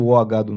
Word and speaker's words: Уагаду: То есть у тебя, получается, дальше есть, э, соответственно, вот эта Нати, которая из, э, по-моему, в оригинале это правильно говорить, Уганду, или как-то так. Школа, Она из Уганды, а школа Уагаду называется.